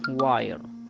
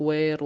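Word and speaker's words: wire: هذا بالخطوة الأولى أن الآي ينطق بحرف الآي إذا أتى الحرف الثاني اللي هو حرف الإي، مثل الكلمة الأولى سمايل، الكلمة الثانية ماين، الكلمة الأخيرة واير. ما ينطق وير ولا ينطق مين ولا ينطق سميل.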